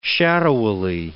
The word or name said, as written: shadowily